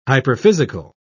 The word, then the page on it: hyperphysical: British English